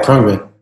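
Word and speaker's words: Khruangbin: Yeah, Krungbin.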